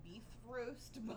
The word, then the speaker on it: Bifrost: Technically, it's roughly pronounced, according to Google, beef roast, but...